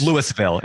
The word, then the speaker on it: Louisville: Not Louisville, Louisville. If you don't say it right, they know you're not from there.